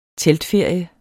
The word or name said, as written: teltferie